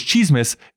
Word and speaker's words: tsismis: What's the Cheesemus is produced by CJSR, Edmonton's campus and community radio station. Download it wherever you find podcasts and on whatsthecheesemus.transistor.fm. Of course, Cheesemus is spelled T-S-I-S-M-I-S.